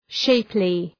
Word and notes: shapely: ‘ʃeıplı

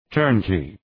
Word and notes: turnkey: ‘tɜ:rnki:

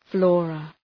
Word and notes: flora: {‘flɔ:rə}

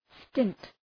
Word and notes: stint: stınt

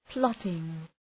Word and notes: plotting: ‘plɒtıŋ

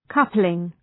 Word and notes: coupling: ‘kʌplıŋ

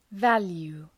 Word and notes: value: ‘vælju:

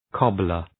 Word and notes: cobbler: 'kɒblər

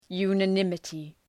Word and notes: unanimity: ,ju:nə’nımətı

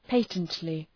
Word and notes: patently: ‘pætəntlı

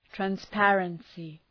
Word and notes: transparency: træns’pærənsı

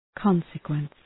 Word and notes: consequence: ‘kɒnsə,kwens

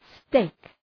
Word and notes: steak: steık

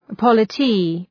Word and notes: polity: ‘pɒlətı

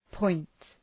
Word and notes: point: pɔınt

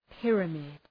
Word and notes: pyramid: ‘pırəmıd

